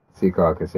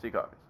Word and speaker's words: Secaucus: Number one, I'm going to need you to help me pronounce this because it's in your state, and I'm sure I'm going to say this wrong. Secaucus, New Jersey? – Secaucus, yeah. – Secaucus, there you go.